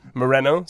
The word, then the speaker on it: Moreno: Do you pronounce it Moreno? – Yeah, Moreno. – Moreno.